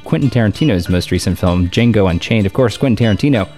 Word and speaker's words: Django: D-J-A-N-G-O. The D is silent.